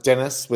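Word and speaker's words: Denis: Dennis Merkus. – And can you spell that for everybody? – Dennis with one N, not the American way.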